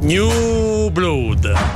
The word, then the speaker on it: New Blood: New Blood. Dico bene, mi pronuncio bene? – New Blood. Bravissimo.